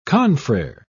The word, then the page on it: confrere: British English